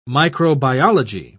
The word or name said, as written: microbiology